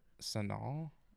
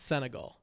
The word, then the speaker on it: Senegal: Senegal? – No, I don't think that's it. I think you're just mispronouncing it. It's Senegal.